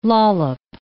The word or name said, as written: lollop